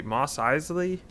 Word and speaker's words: Mos Eisley: Mos Eisley? – Yes, that's one of them, yeah.